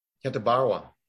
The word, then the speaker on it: Katabarwa: Jean-Vier Katabura? – Katabarwa. – Thank you.